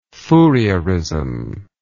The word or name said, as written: Fourieristic